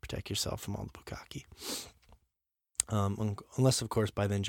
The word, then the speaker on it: bukkake: At least I pronounced bukkake correctly.